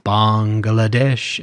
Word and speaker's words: Bangladesh: So it's funny, when you hear it pronounced at the time, it's typically spoken as two words, Bangladesh. And that's like the way George says it in the song, it's saying Bengal nation.